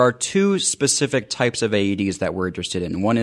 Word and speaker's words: Aedes: Aedes.